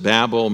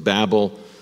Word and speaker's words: Babel: Many people pronounce it Babel, many pronounce it Babel. Scholars are divided about how to pronounce it. I'm just going to stick with Babel. Tomato, tomato. If you say Babel, that's fine, but I'm just going to stick with Babel this morning.